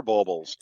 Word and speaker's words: bulbels: Anyways, I think I mispronounced those. Thank you. I was waiting for you to correct me one time, and this is fantastic. Yes, you're right. They are bulbels.